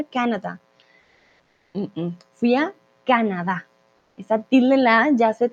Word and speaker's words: Canadá: Entonces no decimos Canadá, no decimos Canadá, no, decimos Canadá. ¿Vale? Esa tilde la hace todo el cambio.